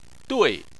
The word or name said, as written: dui4